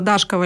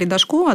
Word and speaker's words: Дашкова: Но княгиня Дашкова или Дашкова, да, два ударения, угу, она предложила как раз-таки использовать вместо И десятиричной буквы О, то есть звук по букве Ё, как обозначается.